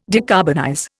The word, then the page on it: decarbonize: Indian